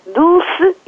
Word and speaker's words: Dulce: Dulce in Portuguese. It's D-U-L-C.